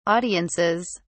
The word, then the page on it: audiences: British English